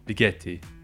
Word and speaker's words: Viglietti: You don't pronounce the G. – Viglietti. – It's G-H-G.